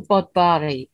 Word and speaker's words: Bodfari: I'm saying Bodfari because that is the Welsh pronunciation. But if you want to look it up, it's spelled B-O-D-F-A-R-I.